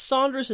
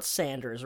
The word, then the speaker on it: Saunders: It's pronounced Saunders and not Sanders, right? – I'm assuming it's Saunders. Sanders is spelled differently.